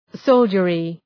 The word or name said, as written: soldiery